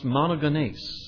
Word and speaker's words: monogenes: The term behind that in the underlying text is pronounced monogenes.